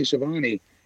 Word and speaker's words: Schiavone: And and then they did a really good interview with Shavoni. I keep saying his name wrong. Tony Schiavone today.